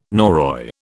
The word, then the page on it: Norroy: British English